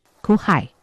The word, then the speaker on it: 去海: 泉州腔的发音呢，就是哭海。 – 其实这个酷害这个音啊，是我们澎湖人的泉州腔的发音啊。